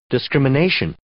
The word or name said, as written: discrimination